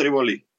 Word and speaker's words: Риволи: Википедия дает Риволи, поверим Википедии, соответственно. – Риволи, да? На первом слове. – Риволи. Википедия дает ударение Риволи. – Я тоже так всегда произносил, но французы, конечно, говорят Риволи.